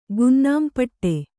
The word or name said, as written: ಗುನ್ನಾಂಪಟ್ಟಿ